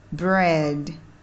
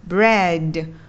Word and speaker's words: bread: body riding riding và âm d nằm ở cuối chữ food food hand hand mud mud bad bad bread bread các bạn để ý là âm d, d là âm được bật hơi ra và mình đừng đọc thành là âm đờ đờ đờ trong tiếng Việt đừng đọc là food hand mud bad bread